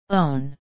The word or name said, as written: own